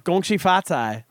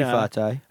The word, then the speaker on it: Gong Xi Fa Cai: It depends on where you are. – Well, how do you say it in Chinese? Oh, yeah, where you are. – Gong Xi Fa Cai is in China.